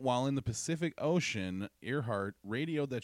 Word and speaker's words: Earhart: Earhart. That's how you actually say it. That's how it's spelled. But it's pronounced Earhart.